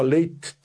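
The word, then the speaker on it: Leitte: A cantora Cláudia Leite, que eu, como tem dois T's, eu costumo pronunciar os dois T's, né?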